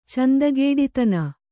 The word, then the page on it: ಚೆಂದಗೇಡಿತನ: cendagēḍitana